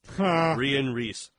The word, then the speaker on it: Rian Reese: It says Ian with an R and rhymes with geese. Rian Reese.